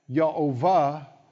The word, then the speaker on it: Yehovah: Now this phrase, if you look up the words, it's the word for Jehovah, or actually it's pronounced Y, Yehovah, and there's other pronunciations than that.